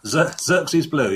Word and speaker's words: Xerxes blue: Yeah, Xerxes blue, yeah.